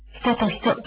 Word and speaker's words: 男と女: 人と人、これは男と女と書いて、人と人と読ませています。